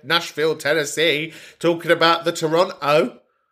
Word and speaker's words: Toronto: If you are pronouncing the second T in Toronto, then you have no right to comment on Toronto.